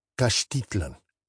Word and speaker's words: Caxtitlan: And Caxtitlan, in other words, Castile, is pronounced Caxtitlan, with the accent on the I.